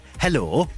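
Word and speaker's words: helo: It's H-E-L-O, but you've got to say it like that. Hello.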